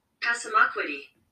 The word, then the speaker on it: Passamaquoddy: Passamaquoddy. – Passamaquoddy.